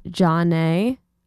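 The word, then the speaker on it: Zhané: Then you heard Hey Mr. JJ by Zane, pronounced Jaune, from pronounced Jaune, released in 1994.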